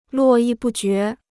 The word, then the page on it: 络绎不绝: luò yì bù jué